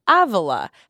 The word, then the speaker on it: Avila: Anyway, so then on the same day, I read that Saint Teresa of Avila had a gauntlet.